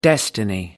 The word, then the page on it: destiny: American English